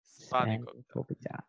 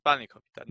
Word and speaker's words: spanakopita: Spanakopita. But the emphasis is on Spani. It's Spanakopita.